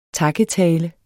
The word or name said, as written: takketale